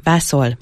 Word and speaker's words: Vazul: A Vazul a Bazil név régi magyar Vazul formájából származik. A helyes kiejtése vászol, vászolj lett volna.